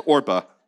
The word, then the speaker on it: Orpah: You say, you just mispronounced Oprah's name. No, that's how it's spelled. It's Orpah.